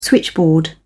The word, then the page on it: switchboard: British English